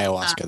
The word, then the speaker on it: ayahuasca: And we were trying to figure out how to pronounce ayahuasca. – Yeah, ayahuasca. That's it.